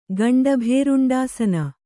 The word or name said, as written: ಗಂಡಭೇರುಂಡಾಸನ